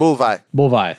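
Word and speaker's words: Buliwyf: Vladimir Kulik played Bullywolf, the heir apparent. How did you say it? – Bullvi, thank you.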